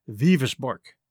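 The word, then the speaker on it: Wewelsburg: The word is spelled Weewelsburg, which looks like Weevilsburg. But I checked the pronunciation, and it's pronounced Vivisborg.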